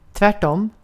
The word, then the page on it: tvärtom: svenska